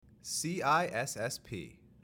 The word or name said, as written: CISSP